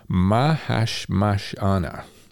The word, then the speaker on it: Mahashmashana: Tune called She Cleans Up, and that's off of his sixth and most recent studio album, Mahashmashana. It's all one word, and I needed to kind of break that up, so I pronounced it correctly.